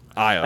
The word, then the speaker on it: Io: That is the most amazing pronunciation for IO I've ever heard. – IO. – I mean, you only have two letters in that, and they're both vowels, and you pronounce one of them wrong.